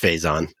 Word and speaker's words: Faison: So Ian Faison, how do you say your last name? I'm not going to say like Alec Baldwin, Faison. – It's just Faison.